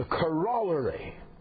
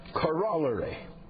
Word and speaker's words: corollary: But and forgive me, I will pronounce this word properly. You Americans all butcher it. The corollary. You will call it the corollary. The last time I looked at it in English, there were two L's in the middle, and that's corollary.